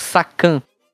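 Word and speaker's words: Sakan (作監): Eles também, às vezes, abreviam é, palavras do, do japonês, por exemplo, Sakan. Sa Sakan se escreve S-A-2-K-A-N. Sakan.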